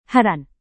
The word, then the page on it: heran: Indonesian